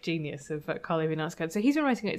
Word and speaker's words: Knausgård: Nauskard? – We decided it's Karl Ove Naus- Knausgård.